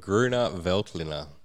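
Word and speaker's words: Grüner Veltliner: It's an Austrian grape called Gruner Veltliner.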